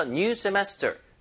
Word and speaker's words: semester: 앞에다 new 붙여서 new semester. New semester. 이거 중간에 강세입니다.